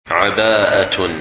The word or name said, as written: Abaah